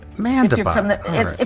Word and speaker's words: Mandebach: It's like Mandebach. – Mandebach. – If you're from the Midwest.